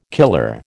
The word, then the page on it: killer: American English